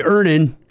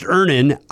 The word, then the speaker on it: Earnin: Earnin. – Earnin. – E-A-R-N-I-N is pronounced Ernin. I don't know how else you would pronounce it, but they really want it clear that that's how to pronounce it.